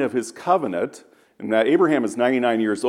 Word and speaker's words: Abraham: And see, in the Hebrew, the suffix is not pronounced. It's just a rush of air. Abraham.